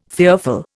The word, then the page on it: fearful: Indian